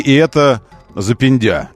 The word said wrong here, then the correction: западня: такая. Западня, как правильно говорить.